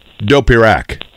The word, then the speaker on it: Dopirak: Is it Dopirak or Dopirak? – It's Dopirak. There's a little bit of a emphasis in the middle there.